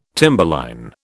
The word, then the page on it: timberline: British English